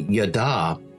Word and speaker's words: yada: It actually is a Hebrew word, and it's pronounced properly, yada, but it's been changed a little bit.